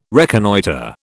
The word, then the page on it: reconnoitre: British English